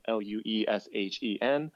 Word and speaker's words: Lueshen: Lueshen. My last name is spelled Lueshen.